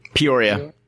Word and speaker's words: Peoria: How do you pronounce it? Peoria? – Peoria. That's where that's where I'm from.